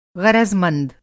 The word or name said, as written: ग़रज़मंद